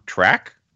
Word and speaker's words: Trec: I have one from Track. I believe is how you say his name, T-R-E-C.